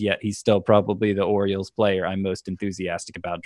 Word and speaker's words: Orioles: The Orioles. – Double stuffed. Next question. – I'm going out of my way to pronounce the L because I know that you call me out for it.